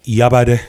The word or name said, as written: Yabade